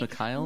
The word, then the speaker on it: Mikhail: I think it's Mikhail. Mikhail. Yeah, okay. Mikhail, like like uh like from Resident Evil Resident Evil except not dead.